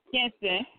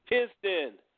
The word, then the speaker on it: Kinston: You hold the state of North Carolina on your back, but you definitely hold Kensington. Man, I can't even say it right. – Kinston, I got you. – Kinston. Thank you.